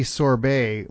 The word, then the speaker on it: sorbet: The original term is what the French call sorbet, S-O-R-B-E-T. It's Turkish and Persian, and I don't know how it's pronounced in those languages. The French do sorbet, and you often find in ice cream shops, well, they'll use the term sorbet on the menu.